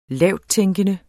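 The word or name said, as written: LAVTTÆNKENDE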